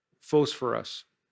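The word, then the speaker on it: phosphorus (φωσφόρος): That the word there for morning star, it's the Greek word, and it's pronounced phosphorus.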